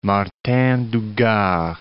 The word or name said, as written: Martin du Gard